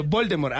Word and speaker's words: Voldemort: Aquí es Voldemort. Sí, digo, Voldemort. Aquí no me lo vienen a pronunciar Voldemort. No, Voldemort. Pero Voldemort me será como Aldemar.